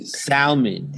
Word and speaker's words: salmon: Salmon. And the correct pronunciation is salmon. – Salmon. – Salmon. That's it. – L is silent.